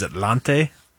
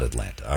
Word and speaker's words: Atlante: But yeah, they're now known as Atlante. – Not Atlanta.